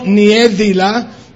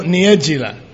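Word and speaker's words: Niediela: Bueno, Niégela es una pronunciación brasilera y se escribe así: N-I-E-D-I-E-L-A. L-A. Pero se pronuncia no Niedila, sino Niedila.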